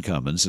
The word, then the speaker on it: Cummins: And I did check in case my brain went into lapse that my name is Ken Cummins, and that's without a G. That's C-U-M-M-I-N-S.